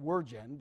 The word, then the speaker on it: wyrgan: I think it's pronounced wyrgan, but it's spelled W-Y-R-G-A-N, which means to strangle or seize by the throat.